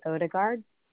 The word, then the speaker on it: Odegaard: Chelsea Odegaard.